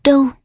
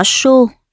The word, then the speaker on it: Trâu: Đọc tiếp: thiếu phụ đáp: thiếp là vợ Trương Tế, người họ Trâu. Chú thích: cũng có người đọc là họ Sô, Sô Thị.